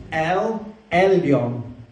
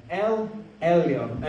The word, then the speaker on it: El Elyon: It's easier if you're Serbian because one of these letters, the L and the J, is actually one sound. Irena, how do you say the L and the J? Lja. Like in millions. Lja. So this is El Elyon. El Elyon. Okay? Can we say it? El Elyon.